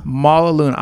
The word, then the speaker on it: Mala Luna: Mala Luna.